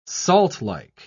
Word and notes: saltlike: British English

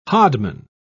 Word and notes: hodman: British English

